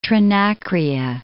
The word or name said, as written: Trinacria